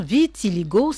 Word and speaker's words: vitiligo: Voilà, c'est la bonne prononciation, vitiligo, cette maladie qui affecte la peau.